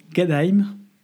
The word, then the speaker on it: Gadheim: Il va désormais se déplacer très légèrement dans un petit village euh, qui s'appelle Gadheim, on le prononce comme ça en allemand.